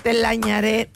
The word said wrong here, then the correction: Telarañero: ¿Cómo se dice? – Telarañero. – Telañanero. – No, telara. – Telarañero. – Sí. Ahora sí.